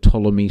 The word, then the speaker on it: Ptolemy: Now, for those of you who've read a little bit about Egyptian history, you'll be aware that Ptolemy starts with a P, but it's a silent P.